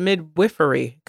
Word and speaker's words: midwifery: You said midwifery, correct? Midwifery is the correct pronunciation.